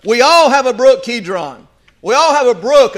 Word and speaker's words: Kidron: The name of this brook is, you can pronounce it several ways, but Kidron.